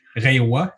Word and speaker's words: Reiwa: Reiwa, ça se prononce comme ça, euh, toi euh, qui sais parler un peu le japonais?